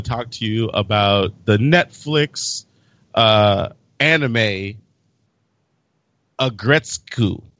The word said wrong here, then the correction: Aggretsuko: Aggretsuko. I said it wrong. It's Aggretsuko, right?